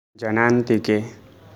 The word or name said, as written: Janantike